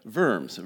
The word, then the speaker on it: Worms: Actually, if we pronounce it correctly in German, it would be the Diet of Worms, okay?